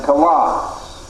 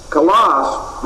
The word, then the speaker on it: kalos: Two of the chief Greek words which are translated good are the words agathos and kalos. Agathos is spelled A-G-A-T-H-O-S. And here's the Greek equivalent of it, A-G-A-T-H-O-S. The other word is kalos, K-A-L-O-S.